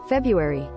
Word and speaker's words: February: February. – Yeah. See, the R is silent in February.